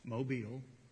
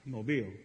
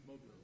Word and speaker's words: Mobile: And it's pronounced Mobile. Mobile. Say it again. – Mobile. – Almost. – Mobile. – Thank you. – Mobile. – You are a Yankee.